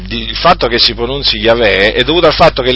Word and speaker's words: Yahweh: Il tetragramma è formato dalla Y, dalla H, dalla W e dall'H. Ecco, questo è il tetragramma che si pronuncia Yahweh. In ebraico diciamo si pronuncia Yahweh